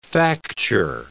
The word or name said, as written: facture